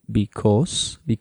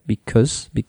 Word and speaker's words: because: Be careful with the pronunciation, of course. So you say because, okay? Es una schwa, you know, because, es, no, you hacer una o, it's like because.